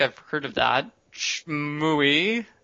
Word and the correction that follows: Shenmue: For the Dreamcast. – What? – Shenmue. – Oh, I heard Shmooey.